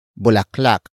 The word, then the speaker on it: bulaklak: And that is bulaklak.